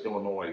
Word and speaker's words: Illinois: Illinois, the S is silent.